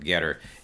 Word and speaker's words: Gettr: It's spelled funny. G-E-T-T-R. There's no E in there, but I believe you pronounce it Getter.